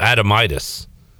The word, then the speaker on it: Adomitis: His name is Cal Adomitz. A-D-O-M-I. Oh, Adomitis. A-D-O-M-I-T-I-S.